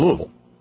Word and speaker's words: Louisville: This one came in from our buddy Corey in Louisville. See, I said it right. Louisville. You know, it's almost like you're just kind of grunting.